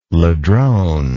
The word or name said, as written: ladrone